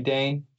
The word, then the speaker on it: Dane: The 0.01% I am on my own, it's Jeremy Dane, J-E-R-A-M-E-Y, because my mom likes to spell it unique, D-A-N-E, Jeremy Dane.